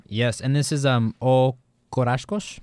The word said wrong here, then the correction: Os Carrascos: Is that how you say it? – Os Corascos. – Os Corascos. Mm-hmm.